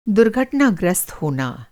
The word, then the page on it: दुर्घटनाग्रस्त: Hindi